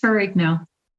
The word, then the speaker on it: Ferrigno: Michelle, I don't think I've ever asked how you pronounce this is your maiden name. – Yeah, it's Ferrigno. – Ferrigno, okay. – Ferrigno, I wanted to make sure. That's the American pronunciation, Ferrigno.